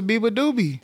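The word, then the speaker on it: Beabadoobee: Yeah, and I didn't even know how to pronounce it until Taylor was like, give it up for my openers, Biba Doobie. And I was like, oh. – Shout out to Biba Doobie. – That's how you say that.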